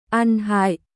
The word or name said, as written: ăn hại